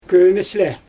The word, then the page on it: kömesle: Xyzyl